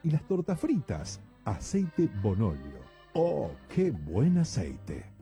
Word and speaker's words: Bonolio: Aceite Bonolio, Bonolio, con acento en la O.